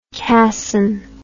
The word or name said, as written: casson